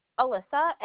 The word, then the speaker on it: Alyssa: My keyboard was that G-R-A-Z? – Yep, I-A-N-O. – What's the first name? – Alyssa, A-L-Y-S-S-A.